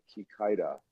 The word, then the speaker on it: Kikaida: And if anyone wants to Google it, it's spelled K-I-K-A-I-D-A, Kikaido.